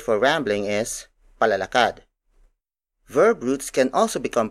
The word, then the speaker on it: palalakad: For example, palalakad, palalakad, palalakad. Palalakad is spelled as P-A-L-A-L-A-K-A-D.